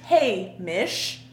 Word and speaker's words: Hamish: Hey, like hey, like hey. Horses. Hey, Mish.